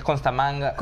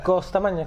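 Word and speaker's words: Costamagna: Siempre me confundo. Con, es Costamanga. Costamanga. Ajá.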